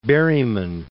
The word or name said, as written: Berryman